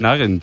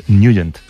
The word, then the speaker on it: Nugent: Claro, yo era de Ted Nugent. – Pero la pronunciación correcta es Nugent. – Te fuiste a Washington y descubriste que era Nugent.